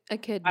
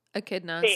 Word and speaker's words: echidna: Echidna. – I looked them up. – Echidna. So the CH is a K sound.